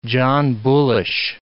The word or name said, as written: John Bullish